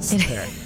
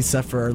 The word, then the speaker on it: Sepehr: By the way, that's how it's pronounced. Sepehr. Just for the since I'm on the radio waves, I know you people that call me Sepehr are listening.